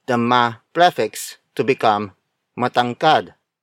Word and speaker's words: matangkad: The next example is matangkad. Matangkad. Matangkad. Matangkad is spelled as M-A-T-A-N-G-K-A-D.